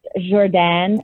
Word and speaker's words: Jordan: And did you know his name is pronounced Jordan, actually? – Yeah. – It's like yeah. – it's French.